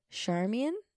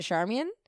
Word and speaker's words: Charmian: But is it Charmian? It's not Charmaine. – Charmaine. – Not Charmaine. – Because it's I-A-N. Is it Charmian? I should know this Charmian.